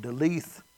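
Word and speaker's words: Daleth: One deal I looked at, it's D-A-L-E-T-H. But one pronunciation looked like you leave the H off, it's delete. Whether it's delete or deleth, I don't know. But anyway, and I looked it up. Delet. Okay. That works.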